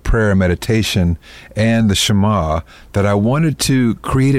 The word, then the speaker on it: Shamah: Am I saying that correctly? – Yeah, Shamah.